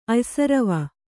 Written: ಅಯ್ಸರವ

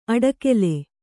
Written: ಅಡಿಗಲ್

